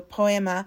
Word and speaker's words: Poiema: The band was spelled P-O-I-E-M-A, so Poema, with Little Flock.